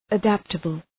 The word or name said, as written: adaptable